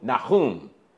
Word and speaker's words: Nahum: You mean the Hebrew pronunciation of that prophet is Nahum? Nahum. Nahum, I'll say Nahum then.